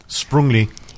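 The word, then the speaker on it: Sprüngli: Sprungli. – Sprungli? – Yeah. – How do you spell that? – S-P-R-U. Well, it's you with umlaut.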